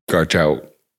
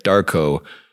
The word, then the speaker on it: Garchow: You know, we're talking about, you know, our friend, Karen Garchow, I've been saying her name wrong you're close. – 20 years. She's like, it's like Jarko. Sorry, I've been calling her Gar Chow.